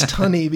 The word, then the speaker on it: Tunney: Tooney. – Well, Tooney sounds better, but I think it's Tunney because it's a double N. The double consonant makes the vowel sound.